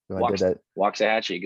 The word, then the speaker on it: Waxahachie: And then you also, before I forget, you have a store and I keep mispronouncing, was it Waxahachie? – Waxahachie. Good job.